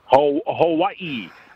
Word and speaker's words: Hawaii: And our boy Matthias is very impressed on how you pronounce Hawaii. You've got it down, the correct pronunciation. – Which I appreciate that game. – I don't, evidently. – Hawaii.